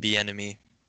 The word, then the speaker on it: Bieniemy: It's like Bieniemy.